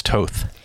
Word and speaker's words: Toth: Starting with an artist that we chose named Toth. T-O-T-H.